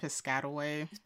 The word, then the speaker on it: Piscataway: But I'm like, Piscataway.